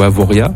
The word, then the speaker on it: Avoriaz: Au niveau mercure, il faut compter cet après-midi 17 à Avoriaz ou Avoria. – Avoria. – Avoria, merci. – On prononce pas le Z à la fin.